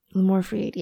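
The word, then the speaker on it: Limor Fried: Is it Freed? Am I saying that right? – Yes. – Lamour Freed, yeah.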